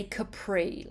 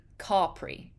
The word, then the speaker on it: Capri: So in America, you would say Capri. Like in America, you say Capri. For this Italian island, you say Capri. But Italians say Capri.